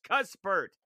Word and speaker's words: Cuthbert: No, he says the way it should be pronounced is Cuthbert. Cuss. C U S S, Cuspert.